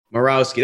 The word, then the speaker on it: Morawski: Morawski, that's what I would have thought, but you never know.